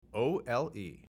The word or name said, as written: OLE